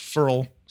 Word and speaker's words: Ferrell: wants his last name pronounced Farrell, spelled F-E-R-R-E-L-L, but pronounced like F-U-R-L.